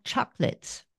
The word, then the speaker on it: chocolat: So the two words, chocolate and chocolat, sound similar, except in French, okay, we have a silent T. It is spelt with a T in the end, but it's not pronounced.